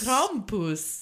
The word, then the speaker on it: Krampus: Krampus.